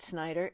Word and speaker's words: Snyder: You know, if you want to write a poem about Snyder, it rhymes with spider.